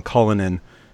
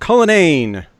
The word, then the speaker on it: Cullinane: I would have used Dave's name wrong if I didn't hear that. I probably would have called him Cullinane. But Cullinane, Dave, how are you? – I am fantastic.